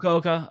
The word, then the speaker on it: Fukuoka: The Fukuoka Convention Center. – It was Fukuoka. That sounds better than what I was going to say.